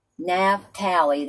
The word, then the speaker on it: Naphtali: And I believe it's pronounced Naphtali Love. – Naphtali. – Naphtali, that's spelled N-A-P-H-T-A-L-I.